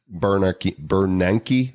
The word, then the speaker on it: Bernanke: And if you look at the show notes, you'll see a bunch of these guys, hedge fund managers, former Federal Reserve chairman, Ben Bernanke, that's how you pronounce it, right? – Yep.